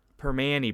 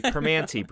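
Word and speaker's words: Primanti: Permanente Brothers. But that's how you say it in Pittsburghese. It's actually Permanente Brothers.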